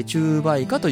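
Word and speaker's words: 虫媒花: ああ、はい、でこういうのをね中媒化、虫が媒介する花と書いて中媒化というんですね。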